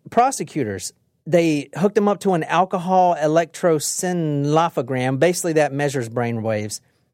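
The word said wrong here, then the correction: electroencephalogram: I can't pronounce it. Electronencephalogram.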